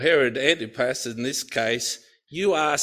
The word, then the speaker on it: Antipas: Herod Antipasta, is that right? Is that right? Sorry, it's Antipas. Sorry, Antipas. A-N-T-I-P-A-S.